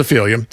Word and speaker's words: Ophelia: There go the Lumineers on ERS with Ophelia. I have no idea why I pronounced it like that. It's just Ophelia.